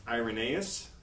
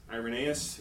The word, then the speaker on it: Irenaeus: And in truth, it wasn't until the second century, and I always pronounce his name wrong, but his name is pronounced Irenaeus. Irenaeus, who was 130 to 200.